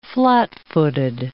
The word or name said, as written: flat-footed